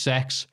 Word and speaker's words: CeX: Number one, it is indeed pronounced sex, something done purely to seem edgy in the 90s.